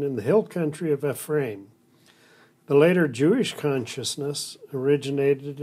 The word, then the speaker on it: Ephraim: Yeah, okay, so it's, you know, you have, it's E, it's a long E, Ephraim.